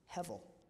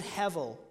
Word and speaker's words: hevel: The English words for vanity or meaningless that we find in our modern Bible translations come from the Hebrew word hevel. It's spelled with a B, but it rhymes with level.